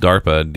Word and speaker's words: DARPA: I think it's called, pronounced DARPA, D-A-R-P-A.